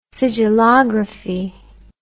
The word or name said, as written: sigillography